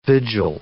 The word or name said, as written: vigil